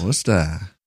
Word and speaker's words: Worcester: This is in Worcester, Mass. – Worcester.